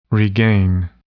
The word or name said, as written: regain